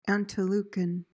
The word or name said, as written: antelucan